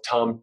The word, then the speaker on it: Thom: That's Travis, last name is spelled Tom, T-H-O-M.